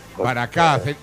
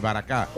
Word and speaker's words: Baracá: Ahora vamos a Pinto, un pueblo del sur, a la sala Baracá, se llama así. – Baracá, acento en la última, sí, Baracá.